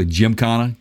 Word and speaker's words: Gymkhana: Gymkhana? – Yeah.